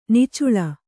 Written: ನಿಚ್ಚಲು

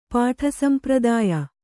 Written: ಪಾಠಸಂಪ್ರದಾಯ

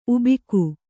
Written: ಉಬ್ಬಿಕೆ

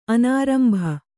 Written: ಅನಾರಂಭ

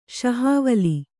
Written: ಶಹಾವಲಿ

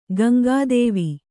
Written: ಗಂಗಾದೇವಿ

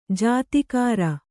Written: ಜೀತಕಾರ

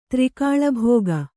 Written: ತ್ರಿಕಾಳಭೋಗ

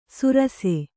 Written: ಸುರಾಸೆ

